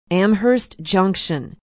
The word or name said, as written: amherst junction